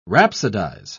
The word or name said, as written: rhapsodize